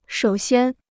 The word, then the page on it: 首先: shǒu xiān